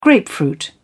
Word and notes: grapefruit: British English

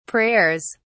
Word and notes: prayers: British English

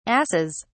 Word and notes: arses: British English

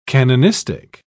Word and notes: canonistic: British English

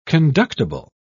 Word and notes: conductible: British English

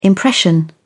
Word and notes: impression: British English

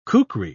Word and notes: kukri: British English

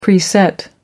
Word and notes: preset: American English

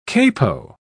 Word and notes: capo: British English

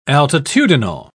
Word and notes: altitudinal: British English

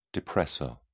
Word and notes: depressor: British English

